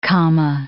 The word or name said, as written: Kama